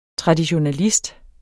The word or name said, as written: traditionalist